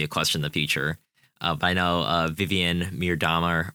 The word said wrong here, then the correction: Miedema: or however you pronounce her name, Miedema. Okay.